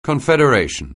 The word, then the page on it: confederation: anglais